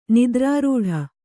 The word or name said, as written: ನಿದ್ರಾರೂಢೆ